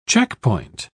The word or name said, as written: checkpoint